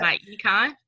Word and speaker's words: MyEcon: MyEcon.